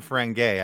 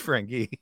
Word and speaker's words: Ferengi: It's not Ferengi, I can tell you that much. It's Ferengi. – It is Ferengi.